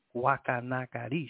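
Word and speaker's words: Guacanagarix: And then the other cacique name, let me spell it out, G-U-A-C-A-N-A-G-A-R, accent on the I-X, Guacanagarish.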